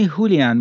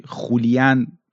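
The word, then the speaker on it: Julián: پدر ماریاس یعنی هولیان ماریاس، که حالا ما تو فارسی خولیان اینجوری تلفظ میکنیم و خب همون هولیان ماریاس،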